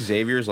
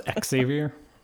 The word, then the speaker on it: Xavier: I think Xavier's like 17-3 and they have 4 games left. – It's pronounced Xavier.